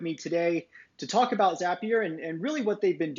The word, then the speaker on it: Zapier: Today I have with me Wade Foster, who is the founder, CEO of Zapier. I just learned is how it's pronounced. Zapier makes you happier.